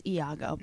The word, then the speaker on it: Iago: It's Iago, but I digress.